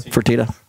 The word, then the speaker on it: Fertitta: Fertitta.